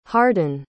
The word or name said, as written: hardin